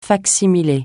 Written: fac-similé

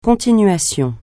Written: continuation

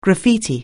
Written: graffiti

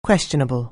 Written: questionable

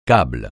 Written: câble

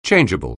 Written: changeable

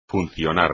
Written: funcionar

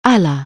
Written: Allah

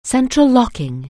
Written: central locking

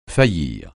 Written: faillir

